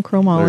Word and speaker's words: chromoly: Anyway, it does have a titanium chromoly frame. Did I say that right? – Titanium chromoly. – There you go.